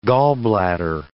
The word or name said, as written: gallbladder